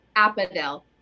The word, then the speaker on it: Apedaile: Apedaile.